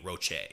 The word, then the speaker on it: Roche: It's spelled R-O-C-H-E, Roche, I think.